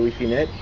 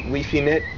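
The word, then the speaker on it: Guifinet: ¿Alguien ha escuchado de Wifinet? Se escribe con G-U-I-F-I, sí. En catalán. Y hay 18.000 nodos. Uh-huh. Es wow. Wifinet y está creciendo a como 10 nodos por día.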